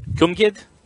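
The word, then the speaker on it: Cwmgiedd: I'm going to call it Chamagin. – Welshland. – I looked it up and I heard somebody pronounce it. It's Cumgid. – Cumgid? – Mm-hmm.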